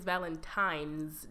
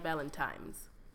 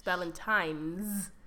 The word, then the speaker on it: Valentine's: My parting message is, it is Valentine's Day, not Valentine's. Okay? It's Valentine's. – Yikes. – With an N, not an M.